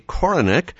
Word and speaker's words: Korinek: My name is Jay Koronek, that is K-O-R-I-N-E-K.